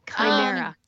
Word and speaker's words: Chimera: How do you say that? – Chimera. – Chimera. – Yeah, and I don't know that they're pronouncing it quite like that. – It's not how it's spelled. It's said different. Chimera. Yeah. That's how I've heard it.